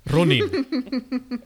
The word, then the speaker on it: Ronin: How do you say it? – Ronin. – Yeah, he says it so much better. I actually, ever since you said that, have only heard it in your voice.